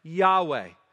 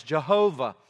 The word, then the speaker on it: Yahweh: So understand this, when in the scriptures you see capital L, capital O-R-D, when you see that, that is a reference to the holy name of God, which most scholars understand to have been pronounced Yahweh. In previous centuries, and in the King James Version, it's kind of been enshrined as Jehovah, but most agree that it was probably pronounced Yahweh. This is the holy name of God.